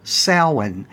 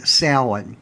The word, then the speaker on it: Samhain: And the M is silent in Samhain, a word that's actually spelled S-A-M-H-A-I-N. I know the first time I saw that word, I pronounced it totally wrong, way off base.